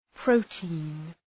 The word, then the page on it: protein: ‘prəʋti:n